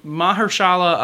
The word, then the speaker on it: Mahershala: Um, Mahash, Mah- okay, I'm, I'm, gonna pronounce his name right. Mahashala Mahershala Ali um, is like the market is totally saturated with him now.